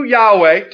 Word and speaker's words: Yahweh: First we see, we make a joyful noise to Yahweh, to the Lord. That's what that's the proper name of God, the tetragrammaton. We pronounce it Yahweh or Jehovah.